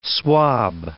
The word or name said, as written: swab